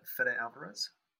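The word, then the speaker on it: Fede Álvarez: Fede Alvarez? – That's him.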